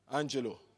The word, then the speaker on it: Angelou: How do you pronounce it? Angelou.